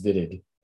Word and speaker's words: Wittig: So it's not wittig, it's vitig.